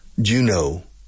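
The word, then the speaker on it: Juneau: And how do you spell Juno, the capital of the capital of Alaska. Well, you spell it J-U-N-E-A-U. E-A-U, yes. Juno. So it's a French word. Well, it looks French to me.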